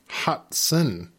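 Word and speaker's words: Hutson: Before that, we had Leroy Huxton. Hudson, if I can say it correctly.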